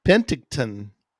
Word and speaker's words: Penticton: Hugh, he's in Penticton. Sorry, Hugh, I hope I said that right. – I don't think you did. – Penticton, British Columbia.